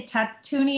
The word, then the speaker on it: Tatooine: So like the second one is, I think you say Tatooine and Rhapsody. I think that's how you pronounce it.